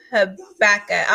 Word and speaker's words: Habakkuk: Okay, so I wanted to go to Habakkuk, and I believe I'm saying it right. Habakkuk. I will spell it for y'all. It is H-A-B-A-K-K-U-K.